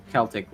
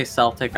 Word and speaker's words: Celtic: Yep, they are part of the Celtic lore. If you say Celtic, I'm sorry, but I've always pronounced it Celtic. – People actually say Celtic? – Yes, they say Celtic. – Like the basketball team? – Yes, like the basketball team.